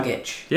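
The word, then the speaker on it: Dragić: I knew I said it wrong. – Goran Dragic. Yeah. – And DeRozan. Yeah. – Dragic.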